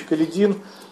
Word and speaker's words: Каледин: генерал от кавалерии Алексей Максимович Каледин. У нас традиционно в Советском Союзе говорили Каледин, вот, но, как мне объяснили эмигранты, да, с Дона происходящие, что нужно ударение именно ставить на Каледин, а не на Каледин.